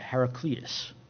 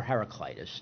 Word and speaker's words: Heraclitus: It was popularized by a man named Heraclitus, or Heraclitus. You see it pronounced or hear it pronounced both ways.